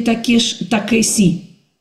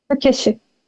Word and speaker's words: Такеши: Таки как правильно, Такеши или Такеси? – Нам всегда объясняли на японском этот звук, что-то между «с» и «ш». Так что ни тот, ни тот вариант в чистом русском произношении будет неправильным. И на мой слух это действительно что-то такое между «с» и «ш», но все-таки ближе к более мягкому «ш».